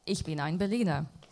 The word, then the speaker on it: Ich bin ein Berliner: Kennedy, de toenmalige president van de Verenigde Staten, een speech in Berlijn met uh, de bekende uitspraak: Ich ben ein Berliner. – Ich bin ein Berliner. – Oh, thank you. That's much better.